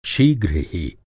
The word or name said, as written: शीघ्र